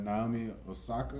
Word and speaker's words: Naomi Osaka: Pro-wise, I'm going to roll with, for Woman of the Year, Naomi Osaka.